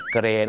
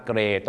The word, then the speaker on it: gray: ๆว่า mom แต่ชาวอังกฤษเป็น M-U-M ก็คือ mum สีเทาก็เหมือนกันครับ gray G-R-E-Y gray เนี่ยนะฮะชาวอเมริกาจะเป็น G-R-A-Y ก็เป็น gray นั่นเองครับอย่าลืมนะครับคำนี้ต้องออกเสียงรอเรือเพราะบางคนเนี่ยนะฮะเห็นฝรั่งใส่เสื้อสีเทามาไปบอกฝรั่งว่าโอ้ I like your gay shirt ชอบเสื้อเกย์ของเขา gray นะครับ gray ต้องคือสี